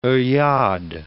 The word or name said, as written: oeillade